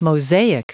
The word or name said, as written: mosaic